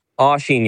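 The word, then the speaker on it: Oisín: Do you pronounce it Oisin or Oisin? – Oisin, yeah.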